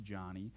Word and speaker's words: Joni: Her name is spelled J-O-N-I and pronounced Johnny, and you may know her because she's been a popular Christian speaker in the past.